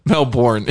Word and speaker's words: Melbourne: Okay. Melbourne is how you pronounce it.